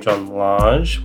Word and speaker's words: John Lange: And we are just past the midway point of the seemingly endless second novel published under the name John Lange, which is actually pronounced basically John Lang.